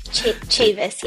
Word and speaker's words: Chavis: Chavis, yes.